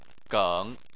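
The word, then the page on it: geng: tone 3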